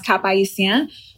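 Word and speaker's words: Cap-Haïtien: So I was actually in Cap Haitien, pronounced Cap Haïtien, and that's the northern city.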